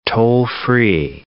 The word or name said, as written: toll-free